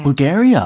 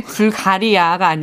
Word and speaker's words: Bulgaria: Bulgaria. 어, Bulgaria. – 아, 이거 발음이 다르네요. – 어, 그러네. 불가리아가 아니라 Bulgaria. 아, 약간 미국식 다 달라요.